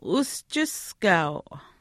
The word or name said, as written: uschiskaau